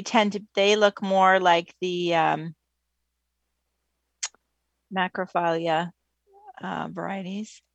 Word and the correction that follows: macrophylla: I continue to say that wrong, don't I? Um, you... – No, macrophylla is right.